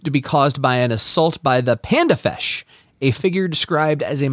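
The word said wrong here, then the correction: Pandafeche: I'm sure I pronounced that wrong, but panda, panda, fesh, f-e-c-h-e. – Panda fece. It's Italy. You got to say it, you know.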